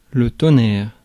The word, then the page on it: tonnerre: [tɔ.nɛʁ]; France